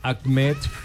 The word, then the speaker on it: Ahmed: Mira, así como lo pronuncio, Ahmed Escaf. – Ahmed Escaf. – Saca un poquito de aire al decir Ahmed.